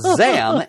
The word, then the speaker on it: Xam: And when he said Zam, X-A-M, which sounds a lot like the second syllable of Shazam, yeah, he could then bring himself back together again.